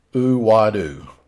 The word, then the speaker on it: Aweida: King Uwadu. King Uwadu, and I'll spell that for you geeks that like to follow this stuff with me, because I'm one of these geeks too. It's spelled A-W-E-I-D-A. Uwadu.